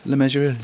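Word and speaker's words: Le Mesurier: Lemessurier. – John Lemessurier, It rhymes with treasurer.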